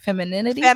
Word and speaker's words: femininity: And you make a lot of videos about femininity. I don't know if I'm saying that word right. Femininity. – Femininity. – Yes. I had to practice saying that word 10 times. – You're saying it right.